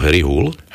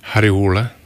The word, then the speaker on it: Harry Hole: Nesbø, áno, tak je to je to naozaj veľká postava, jeho hlavný hrdina tých kriminálnych príbehov je Harry Hole, alebo Harry Hull? – Harry Hule. – Harry Hule.